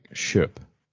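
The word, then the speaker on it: köp: To shop is K O with an umlaut and P. – Oh, right. Like Kalfen. Yeah. – But it's pronounced like shup. – Okay. – Like the K is an SH.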